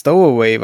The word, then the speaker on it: Stowaway: Habe ich das aus richtig ausgesprochen? Ah, nee, du machst ja kein Englisch, ne? – Doch, doch. Aber so wie ich das sehe, ist es Stowaway wahrscheinlich.